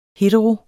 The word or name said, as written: hetero